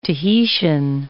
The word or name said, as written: Tahitian